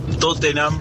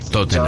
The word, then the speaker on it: Tottenham: Todos los equipos que terminan con ham, uh-huh, H-A-M, bueno, la H es muda. Ejemplo, Tottenham, Birmingham. Creo que la única excepción es West Ham, pero justamente porque se escribe separado.